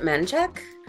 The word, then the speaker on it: Menchaca: There's a, it's more of a road than anything else, but it's spelled Menchaca, but everybody calls it Menchek.